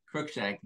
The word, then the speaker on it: Cruickshank: Cruickshank, like the cat from Harry Potter.